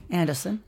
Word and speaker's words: Anderson: You are so far in the game mostly playing as a new character, Saga Anderson. – Anderson. – Which is the Finnish pronunciation.